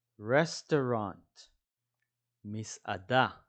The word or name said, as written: restaurant